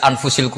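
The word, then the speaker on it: Anfas (أنفس): Di sini ditulis anfasil kutub. Selalu saya baca anfasil kutub karena maknanya kitab terbaik. Kalau kitab terbaik bacanya bukan anfus tapi apa? Anfas. Ya nanti kamu debat sendirilah sama teman-teman ini bacanya apa.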